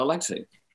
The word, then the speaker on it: Alexei: So I'm a little bit confused, but I am pronouncing it correctly. Alexei, correct? – Yeah, Alexei. – Okay. – Yeah, A-L-E-X-E-I. – E-I, okay. – Pronunciation. I mean, the Russian pronunciation of his, that's his nickname. You know, his father was nicknamed too when he was a little boy. – Right. – But it's definitely Alexei.